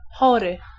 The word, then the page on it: chory: [ˈxɔrɨ]